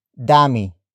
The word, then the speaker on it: dami: Marami is spelled as M-A-R-A-M-I. It means many. It comes from the root word dami. Dami. Dami. Dami is spelled as D-A-M-I.